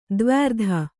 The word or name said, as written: ದ್ವ್ಯರ್ಧ